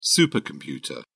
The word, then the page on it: supercomputer: British English